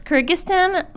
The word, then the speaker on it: Kyrgyzstan: CW, Greece, Indonesia, India, Iran, Japan, Kyrgyzstan. I hope I said that right. Yes, Kyrgyzstan, Mongolia, Nepal, Puerto Rico, Portugal, Russia, Slovenia, Trinidad and Tobago, and there's 15 unknowns.